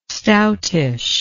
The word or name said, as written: stoutish